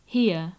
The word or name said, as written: hear